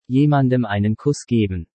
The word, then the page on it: Jemandem einen Kuss geben: German